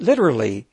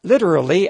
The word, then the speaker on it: littoral: Well, just don't take it literally, even though the name sort of invites you to. Littoral combat ship. Where are Abbott and Costello now that we really need them? No, Costello, it's not literally a combat ship.